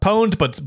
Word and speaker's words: pwned: Pawned? – Brett knows he's hooked up. – Pwned, but spelled P-W-N-E-D.